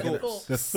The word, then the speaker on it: Thorpe: Some people like say Thorpe. Some people say Thorpes. – No, Thorpe.